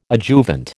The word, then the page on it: adjuvant: American English